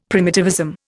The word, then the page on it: primitivism: Indian